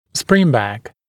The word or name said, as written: springback